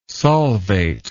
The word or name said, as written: solvate